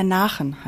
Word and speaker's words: Nachen: Der Nachen heißt das Gedicht. – Wie schreibt man das? – N-A, also wie Nacht, Nachen und dann E-N dran, also ohne das T in der Nacht.